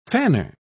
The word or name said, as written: fanner